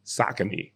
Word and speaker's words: Saucony: Sneaker convo got, shout out to the OGs that brought up the Pro Kids, them Saucony joints that I didn't know had a nice little play. I didn't know Saucony had basketball sneakers. – Yeah, and like, it's funny because it's actually Saucony.